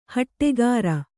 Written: ಹಟ್ಟಿಕಾರ

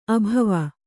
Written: ಅಭವ

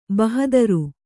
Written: ಭಾಹದರು

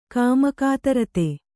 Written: ಕಾಮಕಾತರತೆ

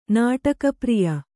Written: ನಾಟಕಪ್ರಿಯ

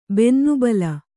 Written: ಬೆನ್ನುಬಲ

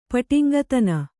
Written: ಪಟಿಂಗತನ

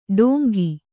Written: ಢೋಂಗಿ

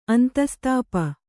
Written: ಅಂತಸ್ತಾಪ

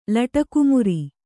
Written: ಲಟಕುಮುರಿ

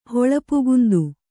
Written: ಹೊಳಪುಗುಂದು

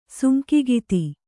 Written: ಸುಂಕಿಗಿತಿ

